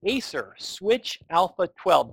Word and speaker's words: Acer Switch Alpha 12: We are going to talk about the Acer Switch Alpha 12. Did I get the name right? – You did indeed.